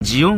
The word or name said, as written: jion